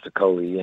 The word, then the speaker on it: Okolie: Lawrence Okolie, is it? Lawrence Okolie, yeah.